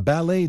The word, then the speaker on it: ballet: A ballet dancer，芭蕾舞演员。 – A ballet dancer。 – Ballet 的拼法是 B A L L E。T，请注意这是一个来自法文的外来词，结尾的 T 不发音。